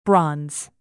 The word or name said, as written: bronze